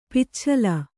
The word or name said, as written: ಪಿಚ್ಛಲ